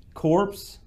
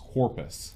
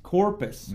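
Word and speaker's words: Corpes: It's I remember reading that his name is pronounced Corpus. – Corpus. Mm-hmm. Well, there you go. That's better than Corpse.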